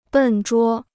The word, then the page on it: 笨拙: bèn zhuō